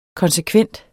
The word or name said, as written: konsekvent